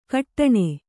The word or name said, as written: ಘಟ್ಟಣ